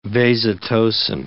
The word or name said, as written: vasotocin